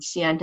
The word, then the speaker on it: Xi'an: Xi'an to Zion. Xi'an means Zion in Chinese.